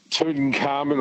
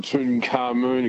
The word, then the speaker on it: Tutankhamun: Tutankhamun or Tutankhamun, you can pronounce it either way.